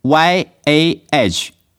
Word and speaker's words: Yahweh: 总之亚伟发音是 YAVE 或作 YAHVEH，其中字母 H 不发音可以省略，这一点已有迹可循。